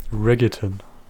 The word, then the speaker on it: Reggaeton: Reggaeton. – Also Reggaeton? – Also schreibt man wie Reggae, nur dann kommt noch ein Ton hinten dran.